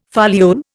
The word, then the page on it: foliole: Indian